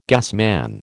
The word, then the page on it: gasman: American English